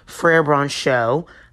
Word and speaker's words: Frères Branchiaux: For those of you who did take French, send me the pronunciation, but it sounds like when I've heard them say it a few times, it's Frères Branchiaux Candle Company, also known as FB Candle Company, which will be on the shelves.